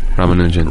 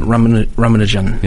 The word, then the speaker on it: Ramanujan: Ramanujan. – Ramanujan.